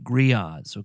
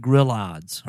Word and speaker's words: grillades: It's got two L's in it, kind of like Spanish. You know, in Spanish, the two L's, the A-ye is going to be pronounced as a y. Well, that's how gree-yahds is pronounced. But it looks like grillades or something like that, but gree-yahds.